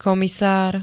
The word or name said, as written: komisár